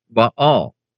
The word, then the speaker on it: Baal: If Baal, B-A-A-L, pronounced in Hebrew, Baal, if that word of and by itself were inherently evil, would God use it in reference to himself?